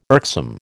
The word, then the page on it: irksome: American English